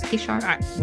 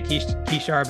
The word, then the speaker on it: Kishar: Is it Kishar or Kishar? I think I'm pronouncing it wrong. – It's Kishar. We say Kishar, but it actually can be pronounced either way.